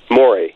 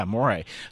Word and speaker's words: Morey: do you pronounce it Morey? – Morey. – Yeah, Morey.